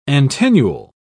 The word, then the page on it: antennule: British English